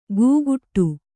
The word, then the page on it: ಗೂಗುಟ್ಟು: gūguṭṭu